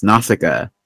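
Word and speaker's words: Nausicaä: I'm pretty sure I had a VHS that had like Nausicaa, because in my head that's how I know that it's pronounced Nausicaa.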